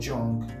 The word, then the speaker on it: Jung: I like this quote by Carl Jung. That's how it's pronounced.